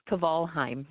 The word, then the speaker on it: Kvalheim: Yeah, so Simone Kvalheim, am I pronouncing it correctly? – It's Kvalheim, yes. – Kvalheim. – Good Norwegian name.